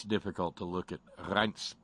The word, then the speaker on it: Reince: His first name is pronounced with the long I sound, Reince, I guess. Reince!